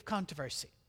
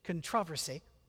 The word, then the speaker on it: controversy: He was not afraid of controversy, or as we'd say it in English, controversy. I don't know why you put the emphasis on the wrong syllable.